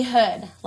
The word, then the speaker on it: Hood: I mean hood, like I'm from the hood.